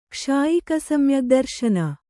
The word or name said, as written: ಕ್ಷಾಯಿಕಸಮ್ಯಗ್ದರ್ಶನ